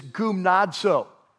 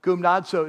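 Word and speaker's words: gumnazo (γυμνάζω): In fact, the word in Greek, and I'd actually like you to write this down in your note sheet. I'm going to give it to you and then I'll spell it in English, is gumnazo, right? Gumnazo. And so you spell it like this, G-U-M-N-A-Z-O, gumnazo.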